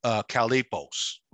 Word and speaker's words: chalepos: And if you take a look at it on what it means, the phonetic spelling of it is kalipos, okay? And it's an adjective, all right? That's the phonetic pronunciation. The transliterated word would be spelled as C-H-A-L-E-P-O-S, okay? Kalipos, okay?